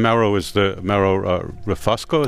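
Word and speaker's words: Mauro Refosco: And Mauro Rufusco, is that how you say his name? Mm-hmm.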